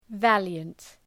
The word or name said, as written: valiant